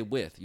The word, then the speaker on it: with: If you ordered a cheesesteak, you don't say with, you said wit. So wit cheese or without.